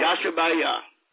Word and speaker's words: Yashabiyah: That's T-A-L-L-U-L-A-H, Louisiana. Zip code is 71284. And my name is Dr. Yashubaya. – Can you give them your name and how to spell it? – My name is, the way you spell my name is Y-A-S-H-A-B-I-Y-A-H. – Okay. – Yashubaya.